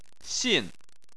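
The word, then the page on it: xin: xin4; tone 4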